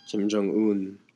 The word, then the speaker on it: Kim Jong-un: And today's special guest will be Kim Jong-un of North Korea. Is that how you pronounce his name? – Kim Jong-un.